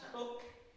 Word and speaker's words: Coke: Back in 1608, Chief Justice Cook, his name's spelled C-O-K-E, and in my ignorance I thought he was Coke, but he's pronounced Cook.